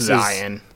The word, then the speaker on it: Zeihan: Peter Zeihan is the guy who was saying that it's pronounced Zion. – Is it really? – Yeah. – Is Zion? – Freaking people. – Yeah, it's not quite pronounced like the name for the Holy Land, but close enough.